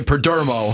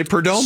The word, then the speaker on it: Perdomo: Somebody said, just pronounce it like Lecomo, and then you'll get it right. Geraldo Perlecomo. – Or just like, always say Perdomo. – Slow down and say Perdomo.